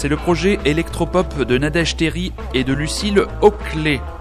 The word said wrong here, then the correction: Hocher: Hein, on va pas hocher, pardon, Lucille Hocher. Si elle nous écoute, la pauvre, si j'écorche son nom, elle va m'en vouloir.